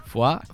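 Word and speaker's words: Foye: Foy, Foy es pronuncia Foy, no? Foy. Més o menys.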